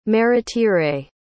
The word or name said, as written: Maritere